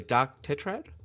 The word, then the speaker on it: Tetrad: tell me if I'm saying this wrong, the Dark Tetrad? Is that? – Mm. Yep, that's right.